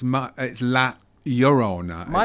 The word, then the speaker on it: La Llorona: this is a new entry in the Conjuring universe, and it's La Llorona. It's pronounced.